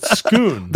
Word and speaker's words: Scone: Actually, you know, the Scots pronounce it Scone.